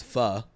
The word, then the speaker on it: pho: You're a foe? – That's what Steven thinks. – Isn't that like a Vietnamese dish? Foe? – I think it's pho, but yeah. – Pho? – That's how you pronounce it.